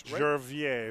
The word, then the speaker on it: Gervier: Well, actually, technically, it's French. – It's Gervier. Right? We have a pronunciation.